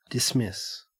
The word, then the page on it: dismiss: Australian English